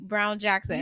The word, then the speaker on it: Brown Jackson: Brown Jackson. – You said it right.